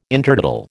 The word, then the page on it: Intertidal: American English